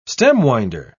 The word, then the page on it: stem-winder: British English